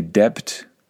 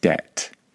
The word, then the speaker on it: debt: I'm not exactly sure why it has a B in it, because that B is completely silent. We do not say debt, we just say debt.